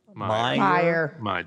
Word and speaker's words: Meijer: We can talk about Peter Mayer. – Meyer. – Who can say? – Meyer.